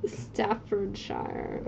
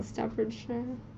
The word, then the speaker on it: Staffordshire: Staffordshire. – Okay, yeah. – Staffordshire.